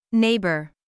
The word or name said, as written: neighbor